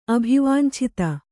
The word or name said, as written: ಅಭಿವಾಂಛಿತ